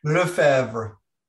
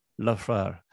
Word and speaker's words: Lefebvre: Is that the right pronunciation? – Lefebvre. – Lefebvre.